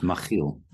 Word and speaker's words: Machiel: Yes, it's a Dutch name, so the CH is pronounced as a ch, and so it's Machiel.